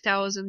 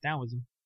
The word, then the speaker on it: Taoism: Except that, you know, they also, like, if Taoism didn't have... – You mean Taoism? – It's weirdly pronounced with a D and a T, so that's what I always try to do.